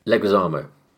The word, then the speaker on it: Leguizamo: Yeah, the weird yellow and red suits, sorry, John, how do you say his surname? – Leguizamo.